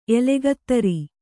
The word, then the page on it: ಎಲೆಗತ್ತರಿ: elegattari